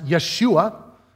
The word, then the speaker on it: Yeshua: Jesus' first name means is actually pronounced Yeshua, and it means God saves.